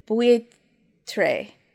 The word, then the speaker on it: buitre: It's buitre. Buitre. – Spell it for me. – B-U-I-T-R-E. – B-U-I-T-R-E.